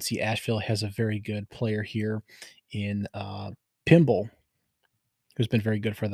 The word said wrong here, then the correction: Pember: Pimbler. Pumble. Still not saying it right. P-E-M-B-E-R. Pimber. There we go, like timber.